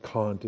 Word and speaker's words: Kant: Immanuel Kant, or Kant, I think Kant is the appropriate pronunciation, but I don't care. Kant, can't, you know.